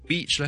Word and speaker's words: beach: 就 beach 咧就用住一个短啲同埋冇咁紧嘅一个 vowel 变咗 it 嘅 vowel，咁嗰个字就变咗一个比较粗鲁嘅闹人嘅一个字。